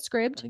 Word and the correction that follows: Scribd: Scribd. Scribd.